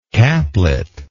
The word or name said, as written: caplet